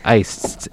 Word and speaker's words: Aests: Aists. – Aists, A-E-S-T-S.